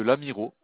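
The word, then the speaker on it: Lamirault: L'Amiro, ça s'écrit L-A-M. – L-A-M, ouais. – I-R-A. – I-R-A. – U-L-T. – U-L-T, ok. – Donc ça vous donne l'Amirolt, en fait.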